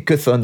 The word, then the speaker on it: Chthon: Céton, je ne saurais pas comment le prononcer, mais Cuthon en anglais. – Cuthon, ça s'écrit C-H-T-H-O-N.